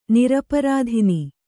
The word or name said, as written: ನಿರಪರಾಧಿನಿ